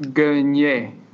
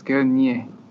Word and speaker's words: Gagnier: It's pronounced Gagnier, though. You got to say French-wise, Gagnier.